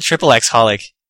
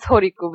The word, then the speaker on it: xxxHOLiC: I call it triple X-holic. – So speaking of another pronunciation thing, the XXX is silent, it's just holic. – Oh, really? – Yeah, it's just Horiku, but, you know, people don't know, like, what the hell is Horiku? – Did Clamp tell someone that, like?